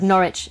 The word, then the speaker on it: Norwich: Man sagt Norwich. Rhymes with porridge. Englisch ist fies, oder? Also es wird nicht ausgesprochen, wie es geschrieben wird.